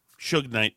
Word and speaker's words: Suge Knight: Suge Knight.